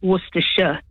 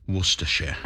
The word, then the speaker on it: Worcestershire: That's the way in England we used to pronounce it, Worcestershire. – Worcestershire.